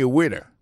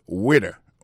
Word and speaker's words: waiter: I am a waiter. Ça veut dire que je suis un garçon, m'a servi dans le restaurant. Alors moi, je prononce le waiter. Waiter, ça veut dire accent tombé sous premier syllabe là. Waiter, W-A-I-T-E-R.